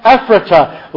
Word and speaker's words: Ephrata: In Bethlehem, Ephrata. That's the Pennsylvania pronunciation. We have a town called Ephrata in Lancaster County.